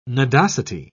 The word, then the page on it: nodosity: British English